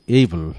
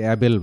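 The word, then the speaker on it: able: প্রথম যে শব্দটি, সে শব্দটি হলো এইবল। শব্দটা কিন্তু অনেকে অ্যাবেল বলে, অ্যাবেল না, উচ্চারণ হলো এইবল, A B L, এইবল।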